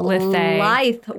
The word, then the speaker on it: lithe: Sorry, I always pronounce that word wrong. A tall, lithe woman. – Lithe. That's how I read it in my brain.